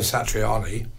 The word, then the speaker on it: Satriani: It's funny, he's the only person in the world who pronounces his name wrong. Because everyone calls him Joe Satriani. But he says, hi, this is Joe Satriani. Joe, your name is not pronounced that way, mate. It's Satriani. We all know that.